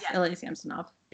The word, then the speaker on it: Samsonov: Only one of those 4 players was confirmed with COVID, which was their goalie, Samsonov. Is that how you say it? – Yes, Ilya Samsonov.